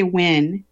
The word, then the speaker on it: Nguyen: So we say Nguyen, N-G-U-Y-E-N. It's Vietnamese.